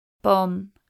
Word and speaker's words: bomb: Silent B is an exceptional spelling. Bomb, atom bomb. Climb, climb up a cliff. Comb, chrome comb.